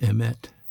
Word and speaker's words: emet: But this Hebrew word for truth, it's pronounced emet, E-M-E-T.